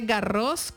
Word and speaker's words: Garros: Ahí va la primera cascarita, se dice, bueno, se dice Garros con S al final porque es nombre ¿así? Propio. Sí, en francés la S no se pronuncia, pero esta sí.